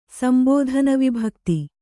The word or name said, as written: ಸಂಬೋಧನವಿಭಕ್ತಿ